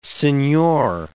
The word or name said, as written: senhor